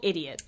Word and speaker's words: ID10T: And so it pronounced ID10T? – It is. – Because someone told me it's called idiot.